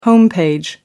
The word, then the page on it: home page: American English